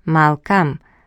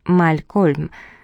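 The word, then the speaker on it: Malcolm: Я готова была без конца слушать, как Эндрю повторяет «Малкам». Так величественно, так воздушно. Но французы не способны произносить это имя как положено, я скоро в этом убедилась. В интерпретации моих соотечественников имя сына звучало как «Малькольм», с произносимой второй буквой «Л» и ударением на второй слог.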